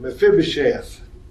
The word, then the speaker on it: Mephibosheth: Ruth is teaching tomorrow on Mephibosheth.